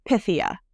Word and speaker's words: Pythia: So the Greek way to pronounce it is Pythia, but it does sound like, yeah.